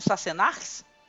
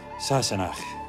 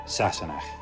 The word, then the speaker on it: Sassenach: Opa, mas como que é que se pronuncia mesmo, Sassenax? Fala aí, Sam. – Oh, sorry. That's not how I say it. Sassenach. An English person. An outsider. Say it with me. Sassenach.